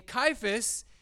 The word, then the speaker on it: Cephas: And Cephas is the correct way to pronounce that word, but it's the Aramaic word, which when translated is Peter or stone or rock.